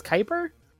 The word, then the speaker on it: Kuiper: You also might know me as Kuiper. Although, if you just read it and you don't think that spelling matches up, it's spelled K-U-I-P-E-R.